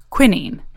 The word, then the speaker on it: quinine: We spoke with Cassia about William Perkin, who is determined to create a synthetic version of a substance called quinine, or as the Brits say, quinine.